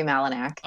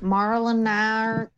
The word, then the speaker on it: Malinak: You know, when telemarketers call, they always add an R in there somewhere. Like Marlinark.